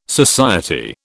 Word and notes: society: British English

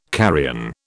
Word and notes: carrion: British English

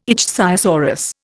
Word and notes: ichthyosaurus: Indian

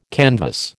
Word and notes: canvass: American English

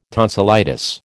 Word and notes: tonsillitis: American English

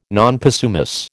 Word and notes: non possumus: American English

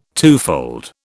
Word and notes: twofold: British English

